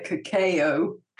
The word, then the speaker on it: cacao: How did you pronounce it? – Call it cacao.